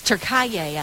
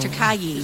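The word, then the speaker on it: Türkiye: It's Turkaye, I think is how you say it. Turkaye. – Is it not Turkey? – No. It's spelt, it's K-I-Y-E, right? – No, this is T-U-R-K-E-I or something like that.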